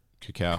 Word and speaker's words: cacao: Cacao? Is that how you say it? – Cacao. – Cacao. – Sure.